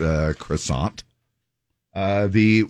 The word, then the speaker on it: croissant: I mean, uh, it, the French way to say it is uh, croissant. – Yes, it is. – So there is the silent T and a silent uh, S and a silent A and a silent C and a silent R.